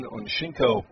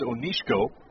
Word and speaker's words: Onshinko: As Ben Onshinko will come on to pitch, he delivers and misses there for a ball. And I will correct my pronunciation. I actually do know it. It's Onshinko.